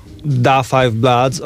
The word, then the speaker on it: Da Five Bloods: a The Five Bloods na przykład, oczywiście... – Da. – da Five Bloods, okej, okay. jeszcze z akcentem trzeba, trzeba by mówić, y, ale ja mówię Leroy na przykład.